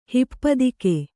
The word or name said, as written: ಹಿಪ್ಪದಿಕೆ